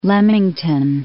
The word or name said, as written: Leamington